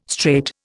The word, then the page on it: strait: Indian